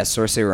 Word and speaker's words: Saoirse: Yeah, Saoirse Ronan.